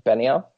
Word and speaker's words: Benioff: And it's worth noting too that I was reading an interview with Weiss and Deboff. – Benioff? – Yeah, Benioff, whatever.